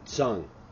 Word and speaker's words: Zheng: His last name is spelled Z-H-E-N-G, but for our fans all to know, you actually pronounce his name Zing instead of Zing, which he had to teach me.